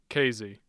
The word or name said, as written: casey